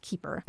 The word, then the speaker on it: Kuper: Keeper Island? – Yeah, but it's spelled K-U-P-E-R, but it's it's pronounced Keeper.